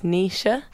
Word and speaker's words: Naoise: You know, even though spelled A-O-I-F-E. – Actually, I've got an Irish friend, and her name is Nisha. – Yeah. – Oh, the way you spell it, N-A-O-I-S-E. That was really weird.